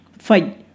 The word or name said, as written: fai